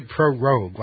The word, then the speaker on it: prorogue: I heard them say prorogue, like rogue. – Prorogue. – And it's got the word pro in front of it, so if you can say prorogue.